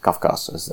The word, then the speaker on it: Kavkaz: They basically say those people from the Kavkaz republics, Kavkaz as they pronounce it, they come over here and they're just criminals.